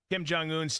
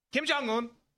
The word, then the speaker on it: Kim Jong-un: But I think you mispronounced his name. – Did I? – Yeah. How's it pronounced? – Kim Jong-un. – Yeah, there we go.